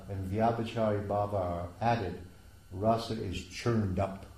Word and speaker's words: rasa: Remember, the word is rasa with a short A. It's a rasa dance with a long A, but it's rasa with a short A.